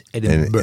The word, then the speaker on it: Edinburgh: Edinburgh. – Well, almost right. It's Edinburgh. It's spelled like you would say Edinburgh, but it is pronounced Edinburgh.